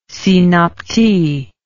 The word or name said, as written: synapte